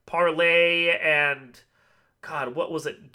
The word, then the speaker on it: Parler: Parler? – Yeah, spelled Parler, pronounced Parlay. – Oh, is it really? – Yes, it is. For whatever fucking reason, it's pronounced Parlay.